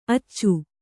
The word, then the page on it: ಅಚ್ಚೆ: Kannada